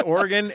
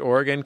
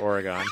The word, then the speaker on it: Oregon: No, you're supposed to say Oregon, and they got bumper stickers up there to tell you to pronounce it Oregon because they're mad that Californians come up pronouncing it Oregon.